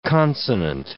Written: consonant

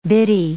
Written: bitty

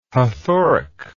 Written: hathoric

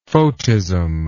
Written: photism

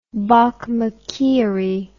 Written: bokmakierie

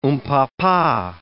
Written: oompah-pah

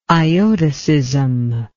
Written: iotacism

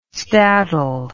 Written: stadle

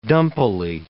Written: dumpily

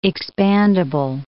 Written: expandable

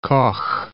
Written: Koch

